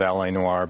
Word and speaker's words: L.A. Noire: But there's this L.A. Noire, okay? And I hope I'm pronouncing it right. – L.A. Noire, right.